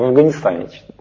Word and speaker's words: Афганистан: Я так привык, у меня много афганцев друзей, в Афгане говорю, в Афганистане читается на самом деле.